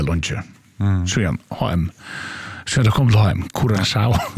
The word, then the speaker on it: Curaçao: Och, ja, och vi kommer att säga att landanavnet Curaçao uttalas Cura, K-U-R-A-S-A-U av vårskan Curaçao.